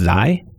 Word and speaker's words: xi: And the next one, I believe it's pronounced xi. It's the Greek letter with kind of like 3 bars. The top and bottom are a little longer. It's xi, yeah.